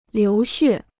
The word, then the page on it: 流血: liú xuè